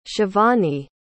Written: shivani

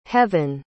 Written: heavynne

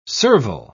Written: servile

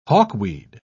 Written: hawkweed

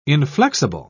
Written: inflexible

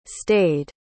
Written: stade

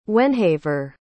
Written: wenhaver

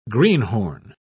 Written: greenhorn

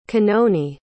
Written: Kanoni

